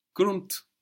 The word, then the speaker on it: Grund: Grund. Good. G R U N D. And that D pronounced like a T. Grund. Round. – Rund. – Rund. Good. And what was soon? – Bald. – B-A-L-D, bald.